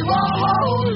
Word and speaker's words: Warhol: Warhol, actually. Hull. As in holes. What? Andy Warhol. Andy Warhol. Like Hull. Hull.